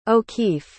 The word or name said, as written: O'Keeffe